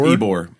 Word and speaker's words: Ybor: Is that how you say it? Ybor. It's Ybor. Ybor.